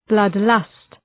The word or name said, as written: blood lust